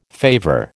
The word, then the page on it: favour: American English